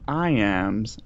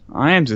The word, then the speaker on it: Iams: As they call me, Iams. Iams is spelled just like the dog food. IAMS, all one word.